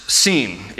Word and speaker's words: sin: If there's a dot over the left branch, it is pronounced sin. If it is over the right branch, it is pronounced shin.